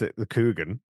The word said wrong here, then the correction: Kurgan: like it's Steve Coogan. – it's Kurgan. Kurgan, yeah.